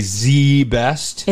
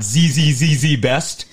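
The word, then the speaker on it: ZZZZ Best: But that is how it's pronounced, is you have to say Z-Best. – It's Z-Best Carpet Company. It's not Z-Z-Z-Z-Best.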